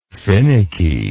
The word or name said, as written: finnicky